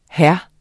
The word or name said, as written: hr.